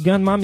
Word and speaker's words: Gunnm: Enfin, ça se prononce Gunma, mais ça s'écrit G-U-N-N-M.